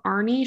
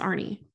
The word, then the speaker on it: Arne: So that is the case of Arnie Cheyenne Johnson. I've actually heard his name pronounced Arn and Arnie. It's spelled A-R-N-E, but I think it's Arnie.